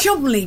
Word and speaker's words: Cholmondeley: Chumley bastard. You're right. Why don't they just spell it Chumley, though?